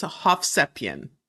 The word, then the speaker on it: Hovsepian: It is Hovsepian.